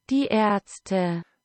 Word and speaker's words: Die Ärzte: Die Ärzte.